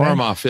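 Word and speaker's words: Ourumov: You've got Alan Cumming as Boris and Godfrey John as General Orimov, I think is how you pronounce it. – Orimov, yeah.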